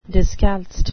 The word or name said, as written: discalced